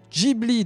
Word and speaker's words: Ghibli: Ghibli, dans qui se prononce officiellement Ghibli et non Ghibli, même si on peut dire les deux.